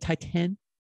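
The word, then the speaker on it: Titane: Number five is Titan, directed by Julia Ducournau. I talked about this. – Why did you say it like that? – She's French. And the film is French. That's why I said Titan.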